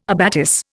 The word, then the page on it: abatis: Indian